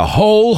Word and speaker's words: whole: Cuidado con la pronunciación de hole. Se pronuncia igual que hole, agujero. Hole. I can see the whole hole. Veo el agujero entero. Resiste la tentación de pronunciar esa W.